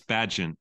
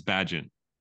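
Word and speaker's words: Bagent: I know, but I want that story to end just as quick as Tyson Bagot. Because here's the deal. I don't even know if I'm saying that right, and I hope I am. – It's Badgin. It's Badgin.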